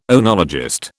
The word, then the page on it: oenologist: British English